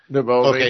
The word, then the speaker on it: Navalny: Yeah, Navalny, okay.